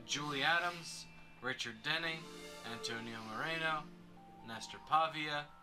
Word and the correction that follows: Paiva: Oh wait, Paiva. – Pivia. – There you go. – Paiva. If we're pronouncing it in the Spanish way.